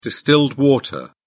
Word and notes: distilled water: British English

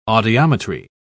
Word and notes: audiometry: British English

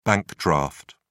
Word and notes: bank draft: British English

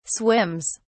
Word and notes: swims: British English

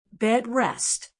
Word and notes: bed rest: American English

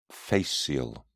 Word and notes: fascial: British English

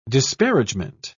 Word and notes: disparagement: British English

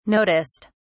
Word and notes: noticed: British English

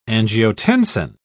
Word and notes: angiotensin: British English

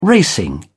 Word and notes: racing: British English

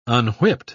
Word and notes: unwhipped: British English

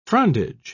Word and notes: frondage: British English

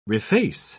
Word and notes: reface: British English

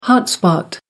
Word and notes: hot spot: British English

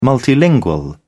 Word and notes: multilingual: British English

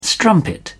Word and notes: strumpet: British English

